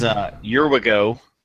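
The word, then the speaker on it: Urwigo: is Yurwigo. That's at least how I pronounce it, right? – Yeah, because there's a W in there, so it's not U-R-I-G-O. – U-R-I-G-O.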